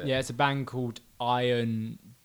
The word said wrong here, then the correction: Ion: Dissonance, not iron, ion, ion. Sorry, I said it wrong.